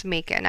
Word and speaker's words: Macon: And thank you to everybody on Facebook that helped me pronounce Macon. I asked everybody Macon if it rhymed with bacon. – Rhymes with bacon.